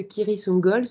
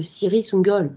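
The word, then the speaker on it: Cirith Ungol: sur le fait que Kiri Sungol se prononce bien avec un C dur, conformément à l'appendice E du Seigneur des Anneaux, et que les membres du groupe ont tort de parler de Ciri Sungol, méchant.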